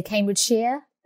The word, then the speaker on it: Cambridgeshire: She lives in Cambridgeshire. Did I say that right? – Yeah, lots of people say it like that. I would say Cambridgeshire.